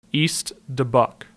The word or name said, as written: East Dubuque